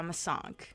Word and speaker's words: Masanque: So then people would like, it's spelled like M-A-S-A-N-Q-U-E, and people would say it as like, Masank? Michelle Masank? I'm like, that's not.